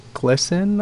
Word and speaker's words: GLSEN: And that one's going to be presented by GLSEN, I believe is the way it's pronounced.